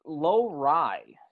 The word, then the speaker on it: Lowry: It's Lowry. So Lowry, like low-rise jeans, baby.